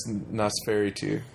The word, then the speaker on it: Nosferatu: Well, they were thinking of, I think, the original Nosferatu? Nosferatu? – Yes, Nosferatu.